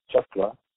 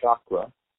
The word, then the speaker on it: chakra: The correct pronunciation is chakra. I hear people saying chakra, which is a completely different word, a completely different root, completely different meaning. It has no relationship with the word chakra.